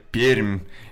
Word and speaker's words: Пермь: И сегодня мы в нашей импровизированной студии в Москве, а наши гости находятся в Предуралье, а точнее в замечательном городе Пермь.